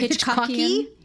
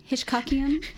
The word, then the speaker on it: Hitchcockian: Cockian? How do you say that? – A little Alfred Hitchcockian. Hitchcockian.